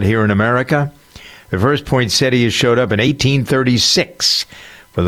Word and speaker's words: poinsettia: Do you know how to pronounce that? Poinsettia, some people pronounce it, but it's poinsettia. That's the way the dictionary tells us. – You have to get that extra syllable in there. Right, exactly. Yeah, there's a T-I-A in there, poinsettia.